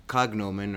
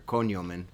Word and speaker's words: cognomen: There was a praenomen, which was their first name, a nomen, which was the last name, and a cognomen or cognomen, or in Italian it's cognome. In Latin, it probably sounds like cognomen.